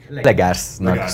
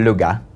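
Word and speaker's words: Léger: Legersnak szoktam mondani. Egyébként francia, úgyhogy Lega. – Lega? – Lega. De Legersként.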